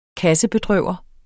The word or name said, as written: kassebedrøver